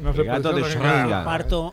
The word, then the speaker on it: Schrödinger: ¿Cómo se pronuncia Schrödinger? Bien. Correctamente. – Schrödinger.